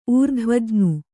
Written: ಊರ್ಧ್ವಜ್ಞು

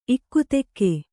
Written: ಇಕ್ಕತ್ತಿಗೆ

